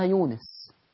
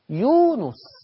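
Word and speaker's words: يونُس: يجي يقولك إيه، أنا النهاردة حفظت سورة يونس، مفيش حاجة اسمها يونس، يونس ضم النون، يونس، عشان كده بننادي حتى احنا اسمائنا عن خطأ.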